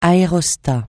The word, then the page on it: aérostat: francais